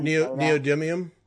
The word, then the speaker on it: neodymium: Is that neodymium? – Yeah, whatever. Those. – Well, that's how you say it. Neodymium. – Neodymium. Yeah. Okay. – Yeah. But I like I like Neil Diamond.